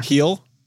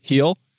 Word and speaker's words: Gil: Heel. Soft G. Heel.